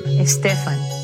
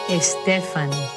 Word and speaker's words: Estefan: But now, here's the right way to say it, from Gloria herself. Listen. – Estefan. – Okay, now, could we hear that one more time? – Estefan.